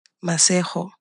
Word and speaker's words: Masego: It's Masego. Okay. – Masego. – Mm-hmm. And that is spelled M-A-S-E-G-O.